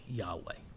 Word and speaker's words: Yahweh: And it's only because of really recent scholarship within the last couple of hundred years that we understand that and pronounce it as, correctly as Yahweh.